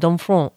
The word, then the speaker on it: Domfront: That's kind of how you say it, Domfront. You really have to like do a little bit of the nasal and express yourself deeply when speaking French.